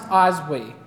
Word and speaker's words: Oswy: I think it's pronounced Oswy. It's O-S-W-Y. So we'll call him Oswy.